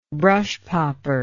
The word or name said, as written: brushpopper